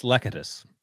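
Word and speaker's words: Lecitus: Is it Lecetus? – Oh, I think it's Lecetus. – Lecetus. Well, Lecetus Smith, Christian Matthew, Jesse Lakuta, and Marquise Hayes. – Sorry, you're right. Lecetus, my bad.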